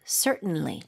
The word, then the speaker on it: certainly: 혹은 이렇게 발음하는 분들도 있어요. Certainly. – 오. – Certainly. – 오, 지금 앞쪽에 강세 주니까 T 사운드가 거의 안 나네요.